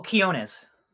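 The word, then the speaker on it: Quiñones: Joe Quinoa? Quinones? – Oh, Quiones. – Quiones. Joe Quiones is the artist.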